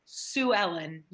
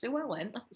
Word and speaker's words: Sue Ellen: I like asked him, I think twice, to clarify, like, so Sue Ellen. Yes, I'm saying this right. It's like, yes, Sue Ellen.